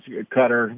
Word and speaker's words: Qatar: Well, I think it's more towards Qatar, which is, I like Winston Churchill, who said you can pronounce foreign words any way you like in your own language.